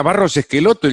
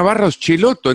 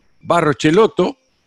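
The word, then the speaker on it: Barros Schelotto: Una peña Barros Esqueloto. Y, él decía, mm, sí, una peña Barros Cheloto. Entonces yo digo, la pronunciación es la que Gustavo Barros Cheloto le está diciendo al periodista en ese momento.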